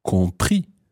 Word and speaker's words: compris: And that irregular past participle is compris. – Compris. – Compris. – Compris. – Now, compris is spelled C-O-M-P-R-I-S.